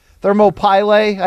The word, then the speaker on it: Thermopylae: Thermopylae. Thermopylae, I prefer that pronunciation, yeah.